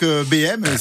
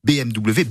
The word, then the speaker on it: BMW: Donc, BM, c'est comme ça qu'on appelle, qu'on prononce de manière familière la marque BMW, BMW, comme disaient les anciens parfois.